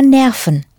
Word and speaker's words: Nerven: Nerves are Nerven, spelled the same as in English, but a V is pronounced like the English F.